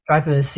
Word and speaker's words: Dreyfus: Dreyfus.